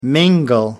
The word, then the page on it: mingle: American English